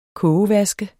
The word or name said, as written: kogevask